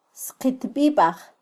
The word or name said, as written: sqitbibaj